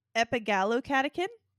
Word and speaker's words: epigallocatechin: Healthline highlighted a study that showed that matcha has high levels of epigallocatechin 3 gallate, also known as EGCG, like R2-D2.